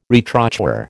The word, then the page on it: retrochoir: American English